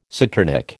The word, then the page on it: saturnic: American English